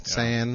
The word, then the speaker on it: Sann: Yeah, it's a common mistake. I mean, S-A-N-N, San, yeah. Even though it's a German name pronounced San.